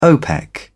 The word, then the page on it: opec: British English